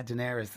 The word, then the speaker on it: Daenerys: as Daenerys. Is that how you say her name? Such a long time. – Yeah, Daenerys. – Daenerys.